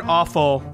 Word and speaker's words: offal: It's super unfortunate that the word awful is pronounced as such.